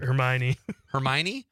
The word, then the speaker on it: Hermione: Paula Krieger, Conrad's mom, says Emma Watson as Hermione. Am I saying that right? I didn't see that. Hermione. What is that word? Hermione? Yes. Is that how you say it? Yeah.